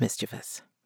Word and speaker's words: mischievous: Mischievous or mischievous? It's mischievous.